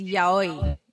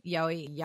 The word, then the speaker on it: Yaoi: It's Yaoi. Yaoi. Yaoi.